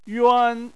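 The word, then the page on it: yuan: tone 1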